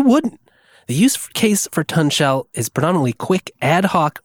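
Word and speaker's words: tunshell: It's pronounced Tun Shell, because, you know, it's like tunnel and shell.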